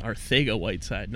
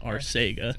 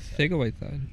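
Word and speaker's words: Arcega-Whiteside: because a lot of people don't realize the C is pronounced like a TH and you're supposed to roll the R because his dad's from Spain and it's pronounced Arthega Whiteside, not Arcega. – Arthega Whiteside.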